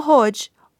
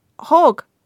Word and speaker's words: Hogmanay: At home it would be Hogmanay, and you hear your mother trying to get the neighbour to pronounce it right. No, not Hodge, Hog, as in pig.